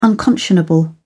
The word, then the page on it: unconscionable: British English